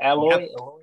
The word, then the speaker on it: Aloy: Is it Aloy? – Yep. I think you said it right.